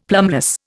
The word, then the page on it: plumbless: Indian